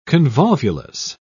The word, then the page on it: convolvulus: British English